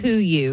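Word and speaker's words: KUIU: It's pronounced Kuyu.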